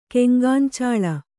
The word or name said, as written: ಕೆಂಗಾಂಚಾಳ